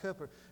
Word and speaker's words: Cowper: spelled C-O-W-P-E-R, but it's pronounced Cooper, that William Cooper was insane.